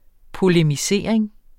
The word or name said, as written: polemisering